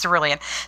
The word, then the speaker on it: cerulean: And there is a blue, kind of a more neon blue in gel pens that is close enough to cerulean for me that I'm, or cerulean, that's correct. Yes, cerulean. See, I thought I was saying it wrong again.